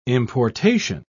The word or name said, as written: importation